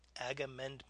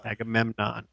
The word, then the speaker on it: Agamemnon: Agamemnon. – Agamemnon.